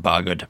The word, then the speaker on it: Bargoed: And you were born in a little place called Bargoed, is that how you pronounce it? That's right, Bargoed, yeah.